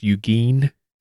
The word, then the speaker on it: Eugene: You know, I think, I don't mean to correct you in front of everybody, but I think it's pronounced Eugene. – Oh, shoot. Eugene. My bad.